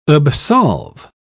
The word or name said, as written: absolve